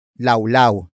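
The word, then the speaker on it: lawlaw: Walwal is based on the Tagalog word laulau. Laulau. Laulau. Which is spelled as L, A, W.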